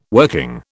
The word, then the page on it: working: British English